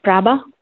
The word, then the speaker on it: Prabha: Prabha.